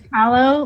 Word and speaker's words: Palo: So it's Palo, like okay. Palo Alto, California.